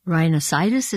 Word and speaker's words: rhinosinusitis: I mean, you have esophageal illnesses, you have GERD, as we mentioned, you have rhinosinusitis, is that how you say it? – Yeah, painful.